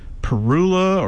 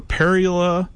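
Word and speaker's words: Parula: Should it be, how should we pronounce it? Perula or Perula? Over the years, I've heard it both ways, and I know what people are talking about, and the proper pronunciation, I think, is really up to you. So, either or either is correct.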